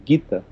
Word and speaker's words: Gita: É, você pega a letra do Gita, você. É, é Gita ou Gita? Eu leio aquilo lá como Gita, Gita né? Gita. Gita. – Não, se escreve G-I-T-A, mas se pegou do, dos, dos, livros clássicos indianos, que são os Gitas.